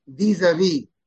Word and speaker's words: vis-a-vis: Since he dealt only with the contractor when hiring him, he has no legal recourse vis-a-vis the workers. – That's pronounced vis-a-vis. – Vis-a-vis. – It's French. Vis-a-vis.